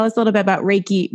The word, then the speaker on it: Reiki: So Reiki, the word itself is spelt R-E-I-K-I. And I break it down into two words, Rei like the sun and Ki, which also is the energy force.